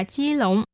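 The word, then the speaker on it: chi lộn (基隆): Trong tiếng Trung thì hai từ này đồng âm với nhau, đều đọc là chi lộn.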